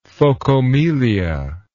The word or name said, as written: phokomelia